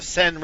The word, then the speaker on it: Seine: The Seine.